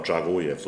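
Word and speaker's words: Dzagoev: Ja, Zagojev, so wird er ausgesprochen.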